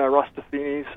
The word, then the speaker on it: Eratosthenes: Eratosthenes. – Yeah, Eratosthenes.